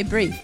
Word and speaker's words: brie: How do you say brie? – Brie. – You've got to roll the R.